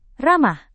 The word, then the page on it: ramah: indonesian